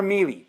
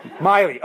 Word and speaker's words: Miele: The brand is M-I-E-L-E. Miley or Mealy? How do you want to pronounce it? Because I'm going to use it several times in today's teaching. – Miley. – Miley. Okay, thank you. Miley it is.